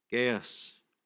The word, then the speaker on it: Gaius: I've heard it mostly Gaius, so we'll stick with that.